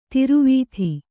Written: ದುರ್ವಾದ

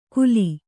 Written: ಕುಲಿ

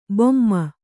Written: ಭೀಮ

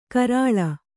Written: ಕೆರಳ್

